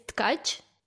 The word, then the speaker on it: Tkach: Is the T silent or the K silent? One of them's got to be silent. – Nothing silent. – Oh, wait. How do I say that? – It's Tkach. – Okay. – It means weaver in Ukrainian. Okay. I don't know. You can try it. It's like catch, but you have to add T at the beginning.